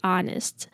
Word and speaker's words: honest: I'm a little skeptical on this point, to be honest. To be honest, 这边的 honest 记得 H 不用发音哦，然后 O 是念 R 的音 R.